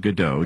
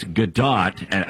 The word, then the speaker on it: Gadot: Now, a lot of people pronounce it Godot. – I thought it was Godot. – But it's Gadot, because I saw an interview where somebody pronounced it Godot, and she says, look, if I was French, it might be Godot, but I'm Israeli. It's Gadot.